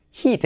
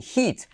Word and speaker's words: heat: Pero el calor como nombre es heat, eso es una I, heat. Se escribe con E, pero es una I, heat.